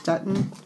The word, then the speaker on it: Dutton: I think the publisher is Dutton, right? That's how you say it. That's Dutton.